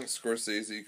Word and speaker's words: Scorsese: Yeah, Martin Scorsese. – I might be butchering that name. – No, you're saying Scorsese correctly.